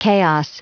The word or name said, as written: chaos